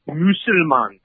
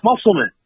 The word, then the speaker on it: Musulman: It's Musulman because it's German. It's Musulman.